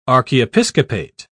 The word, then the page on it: archiepiscopate: British English